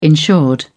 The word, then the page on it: insured: British English